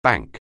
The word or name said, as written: bank